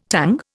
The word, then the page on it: tongue: Indian